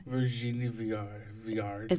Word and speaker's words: Virginie Viard: Virginie Viard.